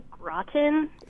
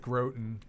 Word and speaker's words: Groton: I live in the town Groton in New York. I think it was pronounced Groton. – Yeah, I pronounced it Groton because I was thinking I was thinking of Croton.